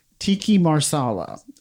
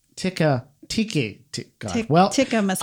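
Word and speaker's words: tikka masala: So actually Stacey, who works for Manit, schooled me, but it is not tiki marsala is not the right way to say it. It's tiki. No, hold on. Tikka masala.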